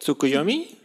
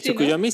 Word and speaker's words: Tsukuyomi: Bueno, Tsukuyomi, que es el dios de la luna, no, no sé si lo estoy pronunciando exactamente bien. – ¿Tsukuyomi? Sí, Tsukuyomi sí.